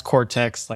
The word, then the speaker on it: Qortex: It's Qortex, pronounced Qortex, like the part of your brain which helps make decisions.